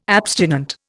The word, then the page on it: abstinent: Indian